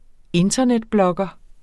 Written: internetblogger

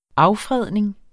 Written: affredning

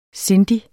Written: sindig

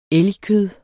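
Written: ELGKØD

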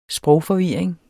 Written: sprogforvirring

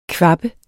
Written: KVABBE

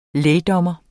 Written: lægdommer

